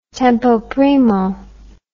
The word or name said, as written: tempo primo